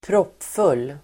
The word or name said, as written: proppfull